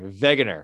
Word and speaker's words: Wegener: Now, back to Alfred Wegener, all right, or Wegener, if you want to pronounce the name correctly.